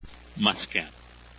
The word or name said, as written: muscat